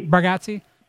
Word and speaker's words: Bargatze: Bargatze,